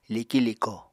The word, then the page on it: liki-liko: Apatani